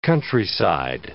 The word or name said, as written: countryside